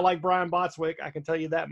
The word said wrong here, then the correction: Brian Bosworth: Brian Bosworth. You Whatever. – Don't even know he said his name right.